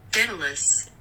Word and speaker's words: Daedalus: How do you pronounce it? – Daedalus. – Daedalus. – Daedalus. – Daedalus. That yes. Makes sense.